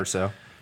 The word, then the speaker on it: Erso: Now, you have Jen and the Erso. – Yes. – Right? Is that how we pronounce it? – Yep. Erso? Jen okay. Erso.